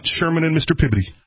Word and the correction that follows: Peabody: Right. – Right? – Right. – You're saying it wrong. – It was Mr. Peabody.